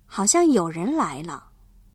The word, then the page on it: 有人: yǒurén